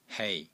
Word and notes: HEI3: Cantonese

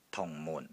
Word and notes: TUNG4 MUN4: Cantonese

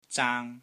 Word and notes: ZAANG1: Cantonese

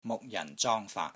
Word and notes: MUK6 JAN4 ZONG1 FAAT3: Cantonese

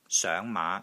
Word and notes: SOENG5 MAA5: Cantonese

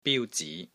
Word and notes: BIU1 ZI2: Cantonese

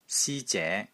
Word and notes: SI1 ZE2: Cantonese

